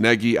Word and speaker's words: Nagy: Nagy, Nagy, it's Nagy, I get it, but that's the guy that I'm going to hire.